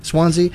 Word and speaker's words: Swansea: Swansea.